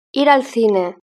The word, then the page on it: ir al cine: European Spanish